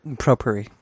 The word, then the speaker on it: Propur: This is the ProPour, one word, P-R-O-P-U-R, Shower Filter Pro Max Chrome Plus. – It's pronounced Propuri.